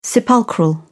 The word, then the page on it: sepulchral: British English